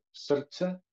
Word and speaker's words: srce: S-R-C-E. So the way you pronounce three consonants in this specific language is you have to treat the R as if it has an U or a vowel in front of it, so it's pronounced srce.